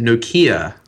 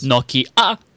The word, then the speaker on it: Nokia: And or Nokia, or how do they say it? Nokia for our American friends. – Nokia. – Who can't pronounce things. – Nokia. – Right. – Nokia. – I say Nokia and everyone's just sort of like, what do you mean? – That's how we say it here. And I don't... – That's how they say it in Sweden. – Yeah, because it's the correct way of saying it. That's why we say it that way.